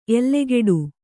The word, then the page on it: ಎಲ್ಲೆಗೆಡು: kannada